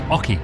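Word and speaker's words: oche: It's pronounced hockey.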